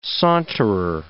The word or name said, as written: saunterer